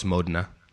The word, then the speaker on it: Modena: Is it Modena? And they said, no, it's Modena. Neither of those. So that's how you pronounce it.